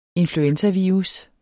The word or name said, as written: influenzavirus